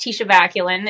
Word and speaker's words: Tisha Vaculin: It's the same, just Tisha Vaculin.